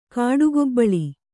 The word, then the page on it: ಕಾಡುಗೊಬ್ಬಳಿ: Kannada